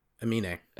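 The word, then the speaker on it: Aminé: Amine. – Amine. Okay. – Yeah. The accent's over the E.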